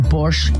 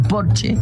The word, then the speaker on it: Porsche: O decir que tengo un Porsche en lugar de decir tengo un Porsche, porque en alemán Porsche se dice Porsche porque todas las S al final se pronuncian, como Marlene Dietrich, por ejemplo, que todo el mundo dice Marlene, pero es Marlene.